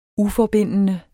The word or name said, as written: uforbindende